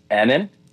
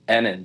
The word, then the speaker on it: Annan: Name is Annan. Two N's. – Annan. Oh, okay. Sorry. – And Ofcoff is pronounced Ofcoff. Just O apostrophe C O F. So Ofcoff. – Nenen Ofcoff. – Annan.